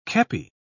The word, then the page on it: kepi: British English